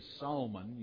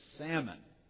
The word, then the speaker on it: salmon: You don't say salmon, you say salmon. That L is silent.